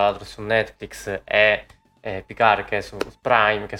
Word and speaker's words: Picard: Eh, poi sarebbe Picard, la D non si dovrebbe pronunciare perché è francese, a me francese fa cacare, quindi lo pronuncio come voglio.